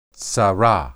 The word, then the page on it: tsarah: hebrew